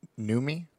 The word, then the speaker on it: Noomi: I think it's Noomi. – It's Noomi Rapace. – It's spelled like Naomi Rapace. Rapace.